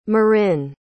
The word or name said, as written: Marin